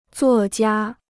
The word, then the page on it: 作家: zuò jiā